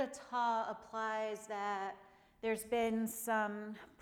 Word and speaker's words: atha: And this week, in the classes I've been teaching, I've been sharing the Sanskrit word atha, spelled A-T-H-A, pronounced atha, and it means now.